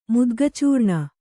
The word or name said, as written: ಮುದ್ಗಚೂರ್ಣ